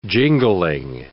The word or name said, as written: jingling